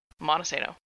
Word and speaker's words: Montesano: And the issues I've been having are I've been saying Montesano. I've been pronouncing the T too hard and saying Montesano. And it's Montesano. Like no T, hard A, or it's Monty. Like Monny, but spelled Monty. And I keep getting that wrong, and people keep calling me out on it.